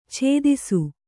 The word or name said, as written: ಛೇದಿಸು